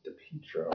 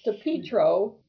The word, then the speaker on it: DiPietro: His last name is pronounced DiPietro. And I know this because Dave Ling, photographer for the Morning Sentinel, uncovered one of Justin's nicknames is Peach or Peaches, as in DiPietro.